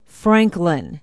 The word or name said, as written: franklin